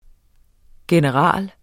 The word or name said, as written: general